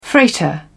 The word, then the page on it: freighter: British English